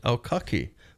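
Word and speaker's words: Aukaki (ʻAukake): Oh, so meanwhile, we're in the month of August, which is pronounced Aukaki by Bingham.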